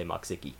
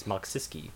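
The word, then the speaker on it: Mokszycki: No, it's M-O-K-S-Z-Y-C-K-I. It actually looks like Maksiki. Anyone reading it phonetically will always say Maksiki. But it is Maksiski.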